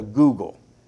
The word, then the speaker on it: googol: The largest number that we have, we have a number that is known as a googol. This is where we get the Google server from. But it's spelled G O O G O L.